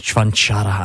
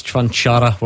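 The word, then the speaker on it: Čvančara: Stephen, Thomas Chvanchara, we're reliably informed that's how you say it.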